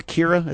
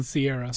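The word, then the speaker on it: Ciara: Do you call her Kira? Is that how you say her name? – Sierra.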